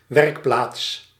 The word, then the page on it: atelier: /a.tə.lje/